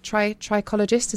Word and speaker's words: trichologist: one of the UK's leading trichologists from the award-winning Wimpole Clinic. Did I say that right? Is it trichologist? Is that correct? – You did. Yes, it's a mouthful, but yes, it's trichologist.